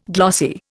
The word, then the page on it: glossy: Indian